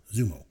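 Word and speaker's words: Xumo: I think that's how it's pronounced. It's X-U-M-O, Zumo.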